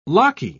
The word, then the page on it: locky: British English